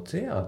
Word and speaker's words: there: で、あとちょっと、あの、 A のところだったんだけど、 A のところの there のところがね、あの、 the ぐらいな感じで、やっぱこの there ってあまり強くね、しっかり発音されることってないんですよね。